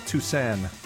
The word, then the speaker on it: Toussaint: So, Alan, you know, you hear his name pronounced multiple ways, but I think the spelling on this label gives us a clue as to how to pronounce it. I've heard people say Alan Toussaint, Alan Toussaint, Alan Toussaint. Well, on his early recordings, he spelled it T-O-U-S-A-N. So I'm going to say Al Toussaint.